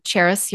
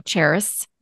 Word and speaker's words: Charis: My name, easiest way to pronounce it and spell it, is to think of the city Paris, but put a CH in the front instead. So Charis Your Life.